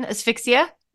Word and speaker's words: asphyxia: How do you say this? It's like asphyxia... – Asphyxiation? Asphyxia? – Yeah. – A-S-P-H-Y-X-I-A? Asphyxia. Okay. – I just never know how to say that.